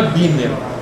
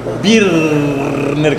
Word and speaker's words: Birner: Não é Biner. – Birner, como dizia o Kifuri.